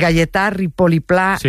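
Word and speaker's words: Gaietà Ripoll i Pla: a ver si lo digo bien, de Gayetà Ripoll i Pla, un mestre y pensador que tenía la seua escola a l'Horta de Rusafa. – Perfecto. – ¿Lo he dicho bien? – Sí, y se ha entendido perfectamente.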